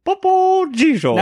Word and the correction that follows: Topo Gigio: Well, Topo Gigio was before our time anyway, so it's okay really that you don't know how to pronounce it.